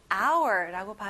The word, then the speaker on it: hour: 여러분, 여기 앞에 h가 있는데 왜 h 소리가 안 나냐고요? 네, 묵음이에요. 그래서 hour라고 발음해요. 그럼 우리 다시 발음 강세 연습해볼게요. 시간, time, time. Good job. 시계, clock, clock. Excellent. 한 시간 또는 시간, hour. Remember, there is no H sound.